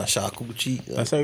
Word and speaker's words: charcuterie: It ain't charcuterie, man. Say charcuterie. – Charcuterie. – There you go.